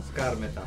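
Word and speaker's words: Skármeta: Skarmeta. – Ok. No, l'accento è sull'alfa. Skarmeta.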